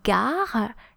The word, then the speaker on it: gare: Now, another mispronunciation that I often hear is la gare, G-A-R-E, la gare, which means the train station, that is often mispronounced and sounded as la guerre. Again, it's a problem of the letter R being pronounced A. La guerre is actually, in French, the war. La guerre, and it's spelled G-U-E-R-R-E.